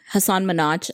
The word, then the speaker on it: Hasan Minhaj: Anyways, I pronounced his name wrong from the beginning. Hasan Minhaj. And I'm sure everyone thinks it's the same thing. So it was nice to hear, you know, how you're actually supposed to pronounce it.